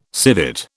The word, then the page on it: civet: British English